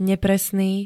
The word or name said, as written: nepresný